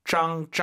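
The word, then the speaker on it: 脏: Z-A-N-G고요. 짱, 짱 세게 발음하시면 Z-H 같아요. 선생님처럼 하세요. 짱 들어볼게요. – 짱. – 이렇게 약간 한글로 표기 안 되는 지읒이에요. 짱 이렇게 발음하시면 됩니다.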